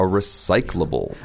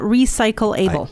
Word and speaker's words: recyclable: So something that can be recycled is called a recyclable thing. – Right. Now, let's talk about the pronunciation. Can you say that one more time? – Recyclable. – Right. So it's not recycle-able.